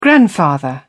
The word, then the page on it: grandfather: British English